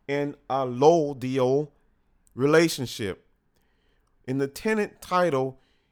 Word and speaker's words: allodial: It's A-L-L-O-D-I-A-L, Allodial. Sorry, I'm saying it wrong. Allodial.